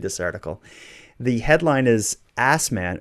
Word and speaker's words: Assman: When Assman, pronounced Osman, applied for a personalized license plate, his request was denied.